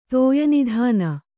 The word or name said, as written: ತೋಯನಿಧಾನ